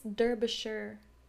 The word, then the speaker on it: Derbyshire: It's Derbyshire.